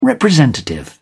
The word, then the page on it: representative: British English